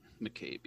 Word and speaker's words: McCabe: McCabe? – McCabe, yeah.